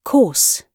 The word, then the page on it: course: British English